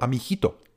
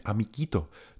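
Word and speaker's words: amiguito: A g a i se vyslovuje jako ch, to by bylo amichito. Ale to není žádný amichito. My musíme mít amikito.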